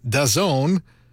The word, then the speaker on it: DAZN: And for this season, new will be streaming on MSG Plus using DAZN, which is how the internet tells me to pronounce D-A-Z-N. If you're looking to stream games, search up D-A-Z-N.